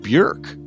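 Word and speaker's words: Björk: The name B-J-O umlaut R-K is pronounced Bjork by most folks in the U.S., but she pronounces it Bjork.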